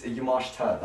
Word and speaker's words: Yamashita: It's Yamashita, the ISM.